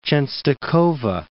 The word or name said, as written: Czestochowa